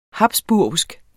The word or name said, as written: habsburgsk